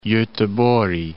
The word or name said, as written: Goteborg